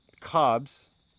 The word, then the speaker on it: Khabs: It's spelled K-H-A-B-S, Cobs. The Cobs is in the coup, K-H-U, not the coup in the Cobs.